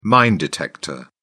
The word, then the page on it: mine detector: British English